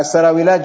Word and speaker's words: السراويلات: السراويلات جمع سراويل، هي هكذا تنطق.